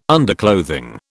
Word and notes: underclothing: British English

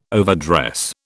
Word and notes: overdress: British English

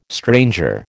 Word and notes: stranger: American English

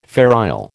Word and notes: Fair Isle: American English

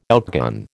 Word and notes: outgun: American English

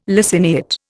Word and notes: laciniate: Indian

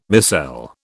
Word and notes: micelle: British English

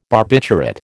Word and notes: barbiturate: American English

